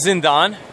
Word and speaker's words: Zindan: I'm talking right now from a book, and you may have to pronunciate it for me. It's Zindan? – Yep. The book is Zindan, and it's an action fantasy series based in the Mughal and Persian Empire.